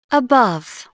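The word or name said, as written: above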